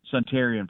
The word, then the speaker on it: Suntarine: But I tell you what, do you say Suntarine or Suntarine, Perkins? How do you say it? – Suntarine, Perkins.